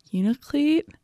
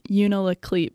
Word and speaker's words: Unalakleet: Unalakleet, or actually, that this is an interesting point. It's spelled Unalakleet, but we were told by a local that to sound in the know, we should be saying Unalakleet.